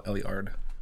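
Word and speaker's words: Éluard: But it's pronounced an Eliardian Instance. – Eliardian. – It's a French poet named Paul Eliard.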